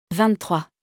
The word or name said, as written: 23